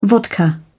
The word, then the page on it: vodka: British English